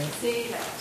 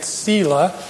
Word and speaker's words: Seele: And, you know, your lovely accent reminded me that somebody during the break told me that I was pronouncing the word S-E-E-L-E incorrectly. Could you pronounce it for me? – Zela. – Zela. Zela. Okay, so I was pronouncing it Sela, and it's wrong. I was wrong. It's Zela.